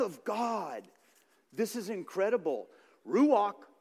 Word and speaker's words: ruach: When this word is pronounced properly in the Hebrew, it sounds like very breathing itself, like ruach.